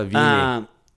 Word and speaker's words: Wiene: It was directed by Robert, I don't know how to say his last name, but I'm going to say Robert Wiene. – No, Wiene. – Wiene. – Mm-hmm. W's the Wienster, are pronounced V in German.